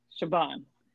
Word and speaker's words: Siobhan: Siobhan.